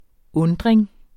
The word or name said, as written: undring